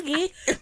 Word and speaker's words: gi: But it's called a gi. – Oh, okay. Because I'm like, did she mispronounce key? Key? – It's for jiu-jitsu. – Well, or judo. – Well, a gi.